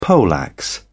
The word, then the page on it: poleaxe: British English